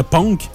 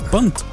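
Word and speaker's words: Punkt: Oui, parce que juste son nom, je le prononcerais punk, mais c'est pas punk, c'est punt. – Punked. – Non, c'est punt. – Punked. – Punt. Punt. On prononce pas le K. – Ben voyons là. – Non, c'est un mot allemand qui veut dire point d'exclamation.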